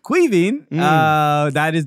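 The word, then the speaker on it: Caoimhín: Koyman. Something like Koyan. – It's pronounced Kuivin. – Kuivin. – I'm not making a joke. That is how it's.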